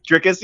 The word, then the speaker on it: Dricus: name. Dricus. – Yeah. Okay. That's good. Dricus.